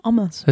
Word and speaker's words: hummus: That's how you meant to say omus, is how you pronounce it properly. I've got a great story about omus, but I think this break will go for too long. – Stop saying omus. – It's how you say it. It's a real way of saying it. Hummus is the Australianized version. It's omus.